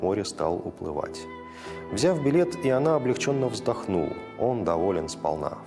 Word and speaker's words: Иона: Да, здесь все-таки с таким с еврейским вариантом произношения Иона, ударение на А.